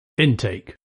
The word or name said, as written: intake